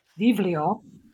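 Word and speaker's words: vivlio (βιβλίο): You could you know vivlio, um, beta, iota, beta, lambda, omicron, vivlio pronounced, you know, the B's, the betas are pronounced like V now.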